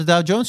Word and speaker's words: Dow Jones: Wat was een, eh, de 125 verjaardag van de Dow Jones.